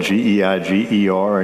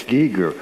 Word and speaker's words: Geiger: it's spelled G E I G E R, and every German would say it's supposed to be Geiger, but in the Carolina backcountry, it's Giger.